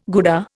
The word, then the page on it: Gouda: Indian